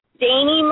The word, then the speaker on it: Dani: Yes, it's Dani Marie. That's right. – All right. – Thank you. I get a lot of Dannys. I'm like, no, Dani's what?